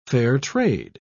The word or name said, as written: fair-trade